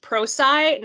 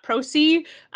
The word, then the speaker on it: Prosci: It is pronounced PROSCI, not PROSCI, which I made a mistake on.